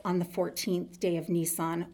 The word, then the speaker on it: Nisan: In the Jewish calendar, it is the 10th day of the Hebrew month of Nisan. It's pronounced just like the car.